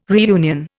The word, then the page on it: reunion: Indian